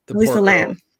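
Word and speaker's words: Elisa Lam: It's specifically about, I'm going to say her name wrong. Alyssa Tam? Alyssa Tam? – Yeah. – Alyssa Lam? – Alyssa Lam. Yeah.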